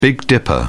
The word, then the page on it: big dipper: British English